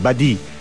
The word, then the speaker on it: Badi: Można sprawdzić, jak to się pisze na skromnej, drobnej, niepozornej podstronie Strefy, ale pisze się tak, jak się wymawia, Badi.